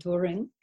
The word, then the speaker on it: Doering: So, first of all, my, my last name is Doring, D O E R I N G. You can pronounce it Doring, it's German.